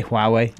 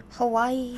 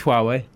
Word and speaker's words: Huawei: But anybody who has a Huawei phone, I always say Huawei. – Is that I've heard so many different pronunciations of it. – Hawaii. – I think it's Huawei.